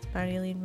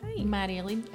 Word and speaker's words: Marjolein: I always say to fuck, like, it's like Mario, like Super Mario. – Mario. – Walking down the lane, like, Marjolein.